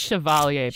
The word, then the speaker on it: Chevalier: I think it's Chevalier. Babe. – Chevalier? I have no idea. I can't speak French. I'm a dumb American. I'm sorry. Thank you for fixing my pronunciation.